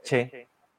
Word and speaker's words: Choi: general Choi Hong-hee, bueno, correctamente pronunciado es Che.